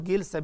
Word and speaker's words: جلسة: من المعلوم أن جلسات الصلاة، وأقول جلسة بالكسر لأن الجلسة يعني الهيئة،